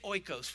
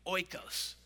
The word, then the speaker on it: oikos: Say oikos with me, oikos. Say it like you mean it, oikos. Like, make friends with it. There you go, oikos.